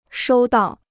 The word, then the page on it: 收到: shōu dào